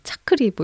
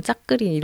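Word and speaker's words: 짜끄리 (จักรี): 짜끄리 아니고 짜끄리 네 네네. 짜끄리 차크리 뭐 이렇게 하는데 타이 발음으로 약간 된 발음이 강하니까 걔네는 뭐 짜끄리 이렇게 하는 것 같아요.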